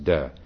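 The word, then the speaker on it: de (德): In Chinese, this virtue is T-E, is pronounced de. So it's the de of the Tao Te Ching, the central text of Taoism.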